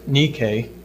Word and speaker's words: Nike: Some of you may have noticed the word N-I-K-E, which I pronounced Nike. There's another word with the same spelling, and it is for that reason that it is that word. It's pronounced Nike, and it's a shoe company that we're all familiar with.